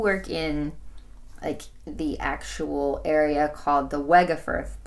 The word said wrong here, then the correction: Wegeforth: Bowl, right? Is that how you say it? Uh, ah, Wegeforth, yes.